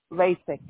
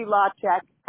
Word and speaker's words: Lacek: LASIK, yes. – LASIK, right? I didn't want to pronounce it wrong, you know. But thank you so much, ma'am. – It could be LACEK.